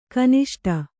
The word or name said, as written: ಘನಿಷ್ಠ